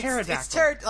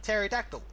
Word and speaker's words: pterodactyl: It's pterodactyl. – Like, that's the whole point. Like, the P is silent. – The P is silent. Yeah. – It's PT. – Yeah. – Pterodactyl.